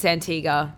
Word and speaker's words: Antigua: Antigua, I think it is, not Antigua. – Oh, is it? – And I'm like, where are you getting the W from? It's Antigua.